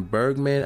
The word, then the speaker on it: Bergman: said Disney Studios chairman Alan Bergman. That's how you say his name, in a statement.